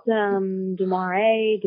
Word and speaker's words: De Mare: People pronounce it Demare, Demare, Demare. It's actually pronounced Demar, but Demare.